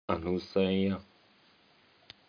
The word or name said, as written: anusaya